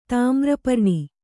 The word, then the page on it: ತಾಮ್ರಪರ್ಣಿ: Kannada